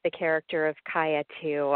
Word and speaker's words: Kaia: Uh, it is pronounced Kaia, K A I A. Yes, it is.